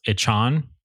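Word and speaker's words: Achane: His last name is pronounced Achain, and everyone was pronouncing it wrong beforehand.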